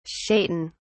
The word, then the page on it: shaitan: British English